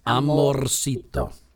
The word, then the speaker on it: amorcito: But you say it quickly, right? You don't have to go so heavy. Like, amorcito. – Yeah. – Did I say it right? – That's right. But now you say it quickly, and it's almost like you don't even roll. You go, ay, qué bueno, amorcito. And it just comes out like this little thing.